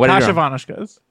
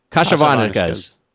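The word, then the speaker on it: Kasha Varnishkes: Kasha Varnishkas. – Kasha Varnishkas. So there's an element. – Why do you keep stopping? – There's something that's silent that I'm accidentally pronouncing because I'm reading it.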